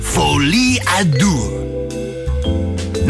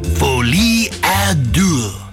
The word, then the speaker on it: Folie à Deux: The title is pronounced Joker Folie à Deux. Now more slowly, Joker Folie à Deux.